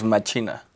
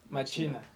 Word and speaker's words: Machina: Le decimos Machina. – Machina. – Okay. – Pero sí está como diseñado como para que eh, le pusieran, o sea, lo, lo, pronunciaran diferente, máquina, machina, machine.